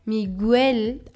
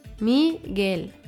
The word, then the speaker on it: Miguel: Es würde Miguel ausgesprochen werden, wenn das U Umlaute hätte. Das heißt zwei Punkte über dem U. Dann würde das U und das E getrennt ausgesprochen werden. Aber in diesem Fall hat Mirel keine Umlaute. Das bedeutet die Kombination aus Re, G im Deutschen mit U und E wird Gel ausgesprochen.